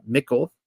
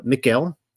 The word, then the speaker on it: Michal: But as the Ark of Yahweh entered the city of David, Michal, and hopefully I'm pronouncing it right, it's kind of like the feminine of Michael, Michal, the daughter of Shaul, looked down from her window.